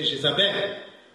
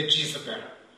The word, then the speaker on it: Jezebel: In this letter, the name Jezebel, sorry, you might be slightly uncomfortable with my pronunciations because I'm influenced by French. In French we say Jezebel, so I'm a bit influenced by that pronunciation. But I will try to say Jezebel, if that sounds strange to you.